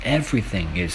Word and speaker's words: everything: 今天的句子是 Everything is thirty percent off here. Everything. is 30% off here. Everything is 30% off here. 这里的话要注意一个发音，everything 这个 th 的发音是一个咬舌音，一个摩擦音。